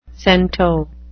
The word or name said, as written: cento